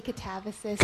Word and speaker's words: Katabasis: I think you can say Katabasis, but I really don't mind how you pronounce it.